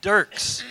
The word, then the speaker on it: Dirckx: It is D-I-R-C-K-X. Now, how do you say this? So all summer when we were interacting in my mind, I was saying Dirckx.